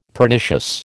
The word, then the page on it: Pernicious: American English